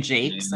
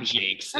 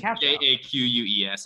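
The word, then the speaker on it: Jacques: Brian Jakes, I think his name is. – It's not Jakes. It's Jakues. – Yeah, it's pronounced Jakes.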